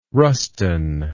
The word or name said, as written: Ruston